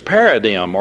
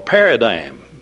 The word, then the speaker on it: paradigm: And one of the words that's used there is paradigm or paradigm. Both ways are ways that the word can be pronounced according to the dictionary.